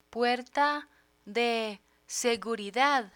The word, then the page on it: puerta de seguridad: Spanish